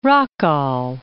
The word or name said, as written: Rockall